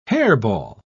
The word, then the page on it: hairball: British English